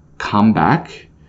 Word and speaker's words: comeback: We can also use the word come back for a couple different other ways. So when we put the stress on the first syllable, that's a comeback.